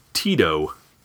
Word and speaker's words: Tito: So, we got the Lugabeast, and that scavenger sitting on top of the Lugabeast is named Tito. Rhymes with Greedo. – Really so, sounds like Tebow.